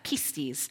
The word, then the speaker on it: pistis: That's what how we pronounce that, pistis.